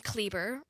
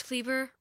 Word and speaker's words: Kleber: My last name, Kleber, it rhymes with Justin Bieber. That's just what I tell people. What's your name, Paige? Paige Kleber rhymes with Justin Bieber.